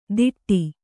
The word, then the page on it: ದಿಟ್ಟಿ: diṭṭi